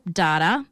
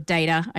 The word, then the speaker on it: Data: But I know him, of course, from The Goonies, Data, and or Data, I should say, is how they say it.